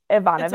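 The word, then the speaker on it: Evanovich: And then, so when I lost my library loan for that book, I got Game On by Janet Avonit. Oh, I'm still going to screw it up, and I like phonetically wrote it out. Ivanovich, okay.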